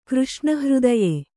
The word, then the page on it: ಕೃಷ್ಣಹೃದಯ: křṣṇa hřdaya